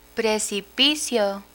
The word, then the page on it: precipicio: Spanish